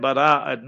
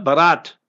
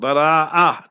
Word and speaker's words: Bara'at: So therefore, shabbe bara'at, not barat. Barat is wrong. Bara'ah. That is how the proper pronunciation should be.